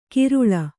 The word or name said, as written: ಕಿರುಳ